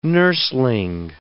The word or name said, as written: nursling